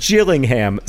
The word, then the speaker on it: Gillingham: It is pronounced Gillingham, soft G like jam, and that's important because Gillingham, hard G like girl, is a different town in England.